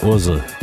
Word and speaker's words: Ursel: It's a German label, so if you pronounce the letters together, they basically say Ursel, which is a name. So I guess that's how you pronounce the name of the label, Ursel.